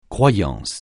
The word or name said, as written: croyance